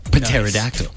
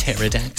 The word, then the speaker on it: Pterodactyl: Plus, it's got a silent P in there. – Pteri. – Pterodactyl. Nice. Pterodactyl.